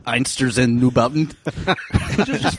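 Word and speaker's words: Einstürzende Neubauten: Einsters in Neubauten.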